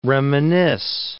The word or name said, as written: reminisce